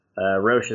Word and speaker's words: Roesch: Roche is spelled R-O-E-S-C-H.